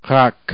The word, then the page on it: רק: rak